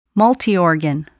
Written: multiorgan

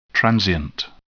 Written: transient

